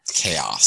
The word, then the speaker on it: KAOZ: Pure K-A-O-Z, pure cows. I don't know how I'm supposed to pronounce that. – Chaos. – Said, oh, there we go. See? – I think it's chaos.